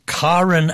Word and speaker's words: Karrin: Hey, that was Karen Allison. Now, you notice I'm saying Karen, but it's spelled K-A-R-R-I-N, but it's not Karen. She calls herself Karen Allison.